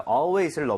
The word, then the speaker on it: always: A-L-W-A-Y-S. Mm-hmm. 발음할 때, 여러분 아시죠? Always가 아니라 A-L은 항상 R에 가깝게 이 모양 벌리셔야 됩니다.